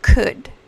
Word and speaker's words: could: Para empezar, la L de, la oración número 2 dos, eh, no suena. Es silent letter. Quiere decir que esta letra no suena. Entonces, para decir could, no dices could, dices could.